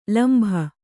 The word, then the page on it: ಲಂಬ: lamba